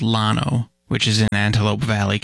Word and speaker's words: Llano: The unincorporated community of Llano is pronounced with an L, despite its Spanish origin.